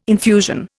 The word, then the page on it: infusion: Indian